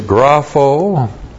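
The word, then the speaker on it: grapho (γράφω): This time chi is a connective chi, correctly translated and grapho. G-R-A-P-H, long O.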